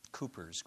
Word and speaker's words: Cowper: Cowper's Grave, spelled Cowper, C-O-W-P-E-R. He pronounced it Cowper.